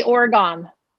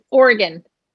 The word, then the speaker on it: Oregon: Well, people always say Oregon, like Calgon, but it's Oregon. But you said it right. You said Oregon.